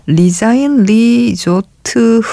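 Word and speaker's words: Les Uns et les Autres: 싶은 어, 음악은 바로 이 영화라고 하시면서 역시 포스터를 어, 올려주셨는데 이걸 리자인 리조트 흐라고 읽는다고 이제 우리말로는 이렇게 표시를 해주셨어요. 제가 알기로는 어, 레정의 레조트 이렇게 읽는 걸로 알고 있는데 에,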